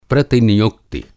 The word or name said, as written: प्रतिनियुक्ति